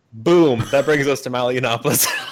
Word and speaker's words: Yiannopoulos: Milo Yiannopoulos.